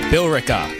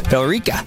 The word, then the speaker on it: Billerica: How do you pronounce it? There's an E in there. – Billerica. – Billerica, maybe? – Billerica. – Oh, that makes sense. Just leave out the E.